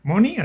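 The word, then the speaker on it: Mhoni: Moni, así, Moni. Porque digo, lleva H entre la M y la O, entonces no sé. – La H es muda.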